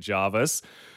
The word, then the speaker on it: Jervis: Your auntie texted you moments after that saying, can confirm it's Jarvis, 100% Jarvis. And now the text line's angry at Jordan's auntie.